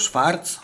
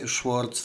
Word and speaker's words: Schwartz: W roku 1932, 2 listopada z kolei urodził się Melvin Schwartz, albo Schwarz, jak wolicie czytać, Schwartz w takiej anglosaskiej wymowie.